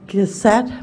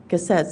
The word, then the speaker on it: Cossette: My name is Elodie Cossette, E-L-O-D-I-E, Cossette, C-O-S-S-E-T-T-E.